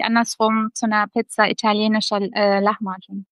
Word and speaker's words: Lahmacun: Also genau, als wäre da so ein CH, also wie beim Lachen sozusagen, ein Lahmacun. – Lahmacun.